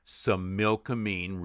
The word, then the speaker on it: Similkameen: I will put right in the script, SIM-IL-KI-MEAN, Similkameen region.